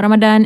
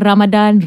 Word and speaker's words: Ramadan: macam actually Take for granted tau Macam dah every year Kita macam okay uh, Semua Ramadan Eh Ramadan Ramadan Ramadan Itulah Actually it's quite important eh To know how it's being pronounced Betul-betul Very true Like you know hmm.